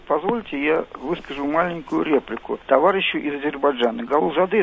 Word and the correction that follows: Гулузаде: если не ошибаюсь, да? Правильно сказал? – Да, ну, немножко вы говорили именно с московским, я бы так сказал, акцентом. Вафа Мирзоевич Гулузаде, правильно? – Ну, ради бога, извините, пожалуйста, не хотел обидеть.